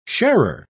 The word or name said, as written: sharer